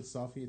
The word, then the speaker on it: Safi: Again, my name is Nabil Safi. It's spelled N-A-B-I-L, last name S-A-F-I.